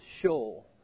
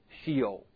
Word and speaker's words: Sheol: Now, if you listen to Max McLean and his British or Scottish accent, he says Sheol, but I say Sheol because I grew up in the South. Okay, and I think most Americans say Sheol anyway.